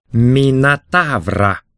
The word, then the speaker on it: Минотавр: «Значит, ты ищешь не Мотора?» «Минотавра», — поправил Тисей, следя за точным произношением.